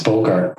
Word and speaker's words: Bogart: It's Bogart, right? So as in Humphrey Bogart.